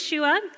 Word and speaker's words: Shua: So my name is Shua. It's spelled S-H-U-A.